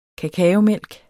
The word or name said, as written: kakaomælk